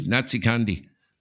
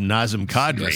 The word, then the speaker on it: Nazem Kadri: So that would be the reference to the mispronunciation that he used to have of Nazem Kadri, yes, that it came out Natsi Kandi.